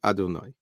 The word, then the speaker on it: Adonai: Says the Gemara, in this world, we write the Shem Hashem with the yud hei and then the vav hei. But that's not how we pronounce it. We pronounce it aleph dalet, meaning Adonai.